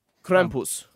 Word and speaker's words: Krampus: Krampus.